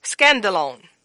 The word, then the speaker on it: skandalon: It's spelled S-K-A-N-D-A-L-O-N. Again, that's spelled S-K-A-N-D-A-L-O-N. and it's called scandalone. Scandalone.